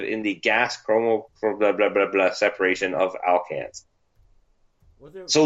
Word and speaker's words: alkanes: separation of alkalines, or alkanes, sorry.